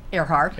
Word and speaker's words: Earhart: But Peggy, tell me a little bit, and how do you say your last name? – Earhart. – Earhart, okay, like Amelia. – No. – No? – Not at all.